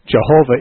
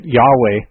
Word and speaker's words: Yahweh: Jehovah is an older spelling and vocalization of it. Yahweh is considered by most scholars to be much closer to its original pronunciation.